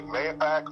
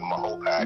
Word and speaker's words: Mahopac: Mahopac. Some people pronounce it Mahopac. Other people pronounce it Mahopac.